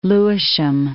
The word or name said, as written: Lewisham